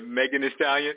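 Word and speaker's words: Megan Thee Stallion: Nibet, he banging Male the Stallion, whoever the fuck, however you say her name, Male the Stallion, Meg the Stallion. – Yeah, Megan the Stallion.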